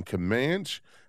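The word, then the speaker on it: Camanche: Let's go to David, who's listening in Comanche, Iowa. I hope I pronounced that right. David, thank you so much for calling. Did I pronounce that right? – You did.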